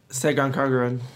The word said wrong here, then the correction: Senran Kagura: He said it wrong with Senran Kagura.